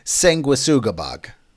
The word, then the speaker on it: Sanguisugabogg: Uh, moving on, we got a new one from a band I'm going to assume is pronounced Sanguisa Sugarbog. Nope. Sure. it's Sanguisugabogg. Close.